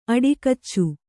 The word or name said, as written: ಅಡಿಕಚ್ಚು